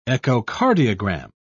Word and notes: echocardiogram: British English

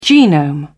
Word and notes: genome: British English

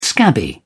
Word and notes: scabby: British English

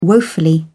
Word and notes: woefully: British English

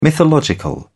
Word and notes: mythological: British English

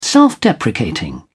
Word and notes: self-deprecating: British English

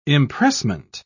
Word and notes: impressment: British English

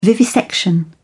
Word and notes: vivisection: British English

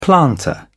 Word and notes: planter: British English

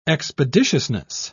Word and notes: expeditiousness: British English

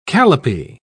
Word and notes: calipee: British English

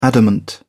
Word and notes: adamant: British English

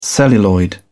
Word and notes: celluloid: British English

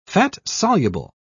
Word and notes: fat-soluble: British English

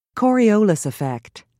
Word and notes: coriolis effect: American English

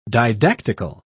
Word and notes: didactical: British English

